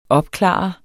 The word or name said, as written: OPKLARET